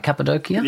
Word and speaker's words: Cappadocia: Did I pronounce it right? Cappadocia? – Yeah, it's correct.